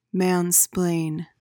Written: mansplain

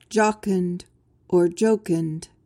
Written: jocund

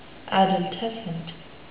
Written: adultescent

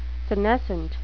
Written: senescent

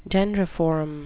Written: dendriform

